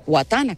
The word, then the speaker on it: Watana: W-A-T-A-N-A, Guatana, con W.